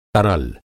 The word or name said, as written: तरल